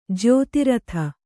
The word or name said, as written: ಜ್ಯೋತಿರಥ